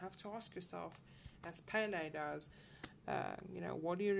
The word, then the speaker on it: Pele: I hope I'm pronouncing that correctly. Let me just check. Pele, sorry. It's pronounced Pele.